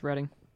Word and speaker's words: Reading: It's pronounced Reading.